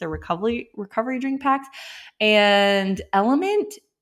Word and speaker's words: LMNT: This is called Element, but it's spelled L-M-N-T.